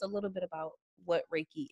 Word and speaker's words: Reiki: listening that maybe they've heard the word, they've probably seen the word, and just now like, oh, that's how you say it is Reiki. That's how you say that word.